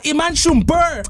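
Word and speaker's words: Iman Shumpert: Tu prononces pas le T à la fin? – Non, Iman Schumper!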